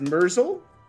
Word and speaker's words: Murzol: Uh, is that pronounced Murzol? Yep. Murzol,